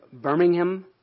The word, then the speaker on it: Birmingham: Joseph was a Baptist minister near Birmingham, England. That's how they say it over there.